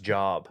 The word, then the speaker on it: Job: Actually pronounced Job. – Job? – You ever read the book of Job? – It's a good one.